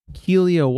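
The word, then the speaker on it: Kealia: Yeah, she's pro soccer star Kelia Watt.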